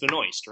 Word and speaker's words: Benoist: Is that how you pronounce it? Is it Benoist or Benoist? It's Benoist, right? – It's Benoist, I believe, yes.